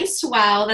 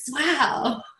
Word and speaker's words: Swow: Yes, my name is Patty Swow. That's like, wow, but with an S in front. And that's my way of getting people to say my name with spirit fingers. Swow.